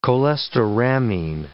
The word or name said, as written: cholestyramine